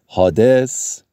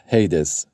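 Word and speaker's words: Hades: آها، اون در واقع اسم دیگه بود یا تلفظ انگلیسیش بود، هیدس. هیدس هم میگن یا هادس.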